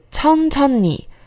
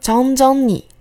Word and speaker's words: 천천히: 接下来一个副词“ 천 천 히”，表示慢慢的。注意一下，前两个叠字都是送气音，注意不要念成什么“ 张张你” 之类的。